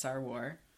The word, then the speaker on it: Sarwar: Sarah pronounced her name for us in our episode that she was a guest on, and I believe it's Sarwar. Like Warrior, because her social handle is Sar Warrior.